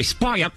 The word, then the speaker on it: spoiled: Esse, aqui, spoiled. Você engole o finalzinho.